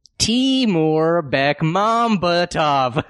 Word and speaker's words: Timur Bekmambetov: It is pronounced Timur Bekmambetov. How many times do I have to say it? – You were wrong.